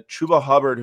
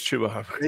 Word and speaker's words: Chuba Hubbard: I think so. Or it's, well, I've been saying Chuba Hubbard for the. – I think it's Chuba Hubbard.